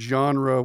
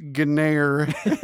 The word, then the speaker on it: genre: I had the same experience when I realized that the word genre wasn't pronounced Gnare.